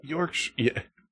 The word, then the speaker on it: Yorkshire: Okay. Yorkshire. That's correct. – That's how us Americans say it.